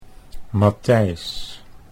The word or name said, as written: Matthijs